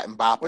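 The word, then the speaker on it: Mbappé: I watched the last few minutes of that, and I couldn't stop watching Mbappe. I don't know how you pronounce his name. – Yeah, Mbappe.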